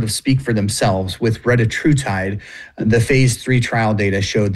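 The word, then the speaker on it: retatrutide: So we've heard it pronounced 100 different ways. The one that I like to go with is retruitide.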